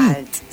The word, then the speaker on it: Isomalt: Y bueno, y contame, Isomalt, ¿lo dije bien? – Isomalt, sí, lo dijiste bien.